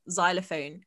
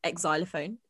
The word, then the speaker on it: xylophone: She can't spell and she can't pronounce silent letters. She still calls xylophone ex-xylophone.